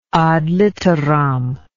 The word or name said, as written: ad litteram